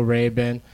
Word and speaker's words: Rabin: I said, is it Rabin? And he's like, no, Rabin.